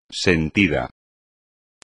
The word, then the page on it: sentido: espagnol